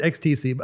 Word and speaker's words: XTC: They pronounce it XTC. I mean, it is supposed to be sort of a play on the word ecstasy. – Right. – But they don't go around calling themselves Ecstasy.